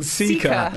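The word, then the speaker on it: Cica: There used to be a sports shoe brand called Zika. The C's and S, the I's and E.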